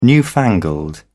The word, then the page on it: newfangled: British English